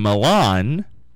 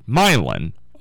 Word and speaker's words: Milan: And remember, this all started this morning because on the news report, I'm saying Milan, Ohio, but it's actually pronounced Milan, Ohio.